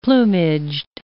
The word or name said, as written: plumaged